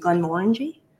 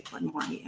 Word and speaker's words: Glenmorangie: Oh, excuse me, Glenmorangie. I first learned it under the wrong pronunciation, so I still say Glenmorangie, unfortunately.